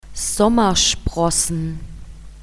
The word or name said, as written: Sommersprossen